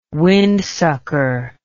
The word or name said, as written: windsucker